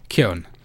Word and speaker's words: Keoghan: How did you pronounce his last name? – Keown.